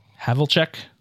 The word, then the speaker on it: Havlicek: Havlicek.